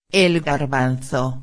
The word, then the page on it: el garbanzo: espanhol